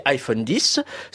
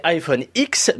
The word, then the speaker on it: iPhone X: Alors avant de me faire insulter par les twittos, parce que je les vois venir sur ma prononciation de ce nouvel iPhone, sachez que ça peut se prononcer iPhone 10, ça peut se prononcer iPhone X, mais ça peut aussi se prononcer combien.